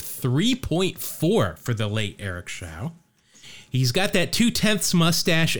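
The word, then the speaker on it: Show: All right, Eric Von Schau rhymes with now, is what I'm told by Baseball Reference.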